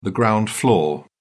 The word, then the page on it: ground floor: British English